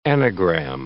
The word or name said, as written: anagram